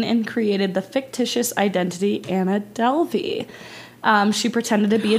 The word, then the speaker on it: Anna Delvey: Anna Delvey.